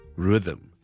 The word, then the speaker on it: rhythm: 有听众问萧叔一个好难嘅字嘅发音，呢个字咧大家都识噶啦，系节奏 rhythm, rhythm, rhythm, rhythm。 It's a very difficult word to pronounce because of the sound in the middle. Th.